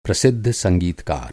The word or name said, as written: संगीतकार